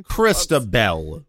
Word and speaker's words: Christabel: Did I say Christabel? I thought I said Krista. – No, you said Krista. You forgot that last... You know what? Okay. I would forgive you that last syllable there.